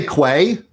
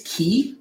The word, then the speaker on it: Quay: How do you pronounce that? – I say Quay. – Okay. That's what you say too. I'm told it's Key. So we bought CoreOS and they were like, yeah, and we're bringing along our product and it's actually pronounced Key.